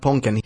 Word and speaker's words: Ponken: I am at Ponken.